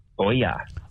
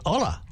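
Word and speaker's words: olla: It's O-L-L-A. That's how I've seen it. – It's Ola. It looks like Ola. – No, you don't say L.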